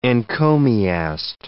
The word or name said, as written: encomiast